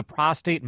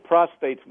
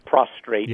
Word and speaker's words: prostate: Well, I think prostate's one of those diseases that, first of all, it's, you know, invariably mispronounced as prostrate.